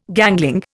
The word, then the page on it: gangling: Indian